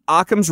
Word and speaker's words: Occam: Occam's razor would tell us. – That's Occam. – Yes. Thank you for the correct pronunciation.